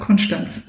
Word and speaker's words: Konstanz: Sagt man Konstanz? Ja. Man sagt Konstanz.